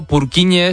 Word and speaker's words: Purkinje: Pero Purkinje es en checo, que es por porque es el apellido del descubridor,